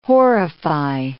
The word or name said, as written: horrify